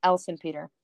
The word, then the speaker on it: Elsenpeter: Elsenpeter.